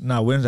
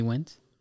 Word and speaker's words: Nguyen: Is that how you pronounce it? This whole freaking time? – Yeah. – Why you thought it was Nguyen? – I don't know. I never had to say it. So I never said it. – Wynn is right.